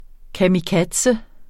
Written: kamikaze

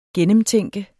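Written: GENNEMTÆNKES

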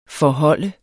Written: forhold

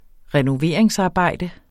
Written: renoveringsarbejde

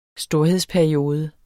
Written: storhedsperiode

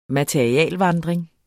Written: materialevandring

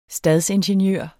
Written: stadsingeniør

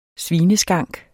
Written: svineskank

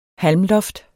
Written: halmloft

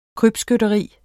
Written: krybskytteri

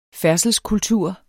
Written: færdselskultur

